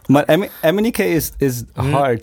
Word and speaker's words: MNEK: Manek? Sorry. – MNEK. – MNEK. – MNEK. No. – Don't worry. Everyone was pronouncing his name wrong at the beginning.